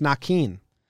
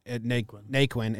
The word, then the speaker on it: Naquin: His name is pronounced Naquin. – I've got to quit. Can I call a 30-second timeout? – I'm not joking. Joey Votto came out and was like, hey, you guys have been saying his name wrong, and he didn't care to correct you. It's not Naquin. Naquin. It's Naquin. And then the reporters went and, like, checked, and they're like, oh, it's Naquin, not Naquin.